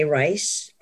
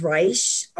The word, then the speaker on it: Reisch: You could look up my name, Renee Reisch, R-E-N-E-E. – Spell it for Ellen. Yeah, spell it. – R-E-N-E-E, and the last name is Reisch, R-E-I-S-C-H.